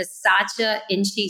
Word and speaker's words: Sacha Inchi: I know you guys are known for, and correct me if I'm wrong, because I'm probably saying this wrong, is it the Sacha Inchi seed? – That's right. Yeah, you nailed it.